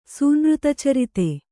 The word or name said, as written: ಸೂನೃತಚರಿತ